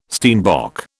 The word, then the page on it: steinbock: British English